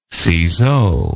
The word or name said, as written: ciseaux